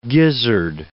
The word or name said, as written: gizzard